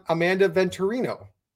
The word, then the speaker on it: Amanda Venturino: And tonight, we have on Amanda Venturino.